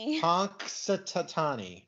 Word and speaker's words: Punxsutawney: So we're going with Punxsutawney is the... – No! – Oh, for crying out loud. – What? How do you say it? Say it again. – Punxsutawney.